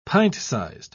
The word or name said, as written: pint-sized